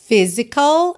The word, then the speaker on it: physical: It's physical, atenção à pronúncia, tá? Physical, a gente tem P-H-Y-S-I-C-A-L, mas a pronúncia, ó, physical, como se fosse F-I mesmo, tá?